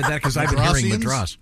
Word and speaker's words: Madras: That seems like a fair By the way, Dean just informed me Madras is incorrectly pronounced Madras, but Americans say Madras.